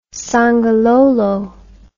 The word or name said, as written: songololo